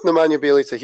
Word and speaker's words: Nemanja Bjelica: I'm going to go with Nemanja Bjelica. He was he used to be a Wolves player, which is why I know how to pronounce his name.